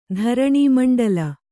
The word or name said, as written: ಧರಣೀಮಂಡಲ